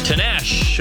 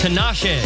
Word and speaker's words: Tinashe: Is it Tinashe? – Yes, well, you know. – That's right. – Oh, yeah. – Actually, it's pronounced Tinashe, but that's all right.